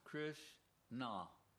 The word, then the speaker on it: Krishna: Two syllables. Krishna. – Два слога. Кришна.